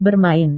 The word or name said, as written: bermain